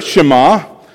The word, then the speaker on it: Shema: Now, it's a Hebrew word, Shema, um, and in case you're wondering how it is spelled, it is in English, the transliteration of the Hebrew is S H E M A, Shema.